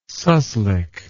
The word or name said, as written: suslik